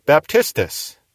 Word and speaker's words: baptistes: Spelled B-A-P-T-I-S-M-A, baptisma. And then we also have the word baptistes, B-A-P-T-I-S-T-E-S. And that word is translated often John the Baptist. That's from baptistes.